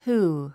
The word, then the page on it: who: húː; フー